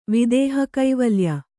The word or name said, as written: ವಿದೇಹಕೈವಲ್ಯ